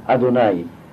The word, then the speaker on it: Adonai: Et je vous dis, enfin non, dans l'étude, je prononce le mot Adonai parce qu'il est écrit Aleph dalet nun yod. Nous disons Hachem lorsque c'est le nom de quatre lettres qui est écrit Yudke Vavke.